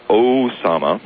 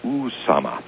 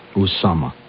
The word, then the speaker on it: Usama: It is the actual correct pronunciation is not Osama, like Iraq. It is Usama. – Usama.